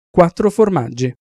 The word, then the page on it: quattro formaggi: Italian